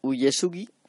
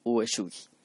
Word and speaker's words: Uesugi: Donde en el relato dice Yedo, pues en el resto he pronunciado y voy a seguir pronunciando Edo. Y donde se dice Uyesugi, voy a decir Uesugi.